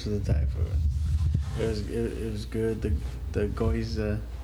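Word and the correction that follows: gyoza: was on point. – The yoza. – Yoza? – Yeah. – Who? – Yoza. – Yoza? – Yeah, that's how you pronounce it.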